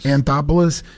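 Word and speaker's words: Anthopoulos: And what's how do you say his name? Adopolis? What's the Anthopolis? – Anthopolis.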